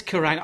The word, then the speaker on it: Kerrang!: Yeah, it's Kerrang. I used to watch, 20 years ago, when I was studying for GCSEs, I would relax on a Saturday night with the Kerrang Music Television Channel.